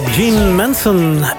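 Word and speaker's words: Jeane Manson: Dat was de Franse zangeres Jean Manson. En toch blijven we hardnekkig haar naam op zijn Engels uitspreken.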